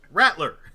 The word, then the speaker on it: Rattler: Rattler. Yeah, there's no E in between the T and the L.